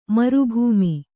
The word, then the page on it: ಮರುಭೂಮಿ: maru bhūmi